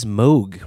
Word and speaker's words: Moog: Um, it is Moog, rhymes with Vogue.